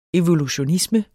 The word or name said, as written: evolutionisme